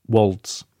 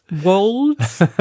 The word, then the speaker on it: Wolds: How do you pronounce that? Lincolnshire? – Wolds. – Lincolnshire Wolds. And North... – That didn't change at all. Wolds. – Wolds. – That's better.